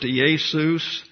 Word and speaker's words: Iesus: So that is pronounced Iesus. There is no J in Greek, and therefore when we transliterate Iesus, we're left with the I as the beginning letter.